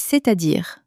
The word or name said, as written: c'est-a-dire